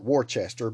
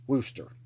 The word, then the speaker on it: Worcester: And it's spelled out Worcester, but I have an aunt and some family who live in Massachusetts, so I know that's pronounced Worcester.